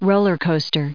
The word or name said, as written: roller coaster